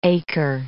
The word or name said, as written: acre